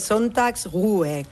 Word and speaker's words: Sonntagsruhe: A ver, el Sonntagsruhe, es una palabra difícil de pronunciar aún así, es Sonntagsruhe, que al final es descanso dominical, básicamente.